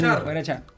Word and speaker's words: guarachar: voces, acordeón, guitarra, bajo, rap y percusión para guarrachar. guarachar, ¿lo digo bien? – Sin, guarachar.